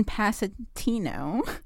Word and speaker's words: Passantino: Passantino. I hope I'm pronouncing that right. – How's that spelled? – P-A-S-S-A-N-T-I-N-O. Passantino. – Passantino. – Okay, great. – You got it.